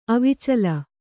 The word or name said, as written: ಅವಿಚಲ